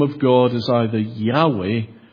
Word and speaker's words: Yahweh: Now, there are two main suggestions as to how that name is pronounced. One, I already slipped in there. Yahweh. That is the majority position, well established. But there is a minority position that says that the sacred name was pronounced Yahovah.